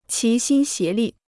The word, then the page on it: 齐心协力: qí xīn xié lì